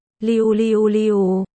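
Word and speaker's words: Liu Liu Liu (六六六): Được viết là Liu Liu Liu. Đọc là Liu Liu Liu. Có cách phát âm khá giống với New, New, mà New trong tiếng Trung có nghĩa là tài giỏi.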